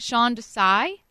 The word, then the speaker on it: Sean Desai: Am I saying this right? Sean Desai? – Yes. – Yeah, you are.